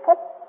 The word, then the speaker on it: phú: Còn cái chữ đó đó mà khi mà có nghĩa che giấu thì ta là đọc là chữ phú. Chứ chữ phú này không phải là chữ phú là giàu có.